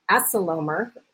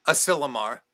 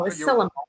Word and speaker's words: Asilomar: 3 times, and was selected to be the keynote performance at the annual Asilomar. Did I say that right? – Asilomar. – Oh, Asilomar. – You were close.